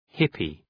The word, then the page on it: hippy: ‘hıpı